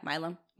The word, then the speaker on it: Milam: Milam, is that how you pronounce it? – I think so. Yeah, Milam.